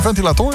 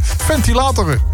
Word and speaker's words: ventilator: De klemtoon moet je anders leggen. Of zeg. Het is geen ventilator, het is een ventilator.